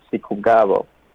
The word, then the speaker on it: Sikugabo: How do you pronounce your last name? – Well, it's my mom. She really, doesn't like when people, you know, unpronounce my name, but, it's not that easy to pronounce the last name, you know, but this guy, my name is Sikugabo.